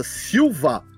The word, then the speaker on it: Silva: A pronúncia japonesa, Silva, é Silva, porque o A, ele é prolongado porque ele faz o silver, né? – De prateado, em inglês.